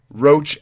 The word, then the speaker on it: Roch: It's R O C H. Roach as in the bug as in the vermin. – Okay, his name is Roch Therol. – Roach. – Ro- I will say Roach, but just so if anyone wants to Google him and Roch Thero.